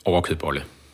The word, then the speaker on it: Overkødbolle: og men jeg vil lige bruge ind i en anden kontrovers, jeg har hørt om, fordi nu er du overkød øh, hoved. Var det rigtigt udtalt? – Overkødbolle. – Overkødbolle.